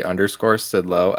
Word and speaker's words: Sidlow: Yep, at Scott, S-C-O-T-T, _Sidlow, S-I-D-L-O.